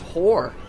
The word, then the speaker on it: Hoar: so they named it H-O-A-R, which is, as you can imagine it, pronounced whore.